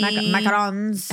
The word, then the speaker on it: macarons: So I got macaroons from Napa. – That's not how you say it. – Macarons. – How do you say it? – Macarons.